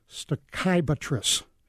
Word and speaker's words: Stachybotrys: It's actually, now you're going to hear this word pronounced 12 different ways from 12 different people. Black mold is called stachybotrys. That's how I say it. I think that's how you should say it.